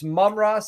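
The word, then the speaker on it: Amon-Ra: How how is it pronounced? I always thought it's Mumra. It's Mumra St. Brown. – Yeah, that's it. Mumra.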